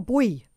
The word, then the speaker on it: buoy: First of all, is it boy or buoy? – It depends which continent you live on. – I live on Africa's continent. – Yeah. Boy is how we pronounce it. I think the Americans have trouble with words like root and boy.